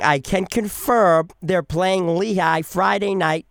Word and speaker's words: Lehigh: And then they play Lehigh. Am I saying that right? – Yep, Lehigh is correct.